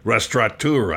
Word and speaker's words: restaurateur: You got to say that right. There's no N in it. Restaurateur.